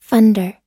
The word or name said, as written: funder